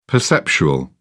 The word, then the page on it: perceptual: British English